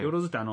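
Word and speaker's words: 萬: よろずってあの万っていう字の難しい字を書いて、でこれでよろずと読むみたいなんですけど、まあ振り仮名を打ってくれてますよね。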